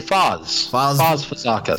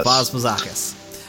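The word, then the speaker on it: Faz Fazakas: But this is also one of the first Muppet productions that had radio control puppet effects that were designed by Faz Fazakis. I don't know if that's how you say his name, but... – Actually, Foz Fazakis. – Foz Fazakis.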